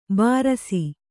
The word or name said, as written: ಭೃಶ